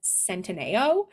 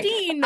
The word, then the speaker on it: Centineo: I've seen him in a lot of things, and it's so interesting how you pronounce, because I've always said Noah Centineo. But yeah, who knows what's right? – Centino. I think it's Italian.